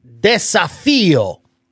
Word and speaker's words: desafío: Desafío. – Desafío? – Desafío. – Can you pop it up accent on the I.